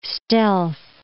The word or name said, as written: stealth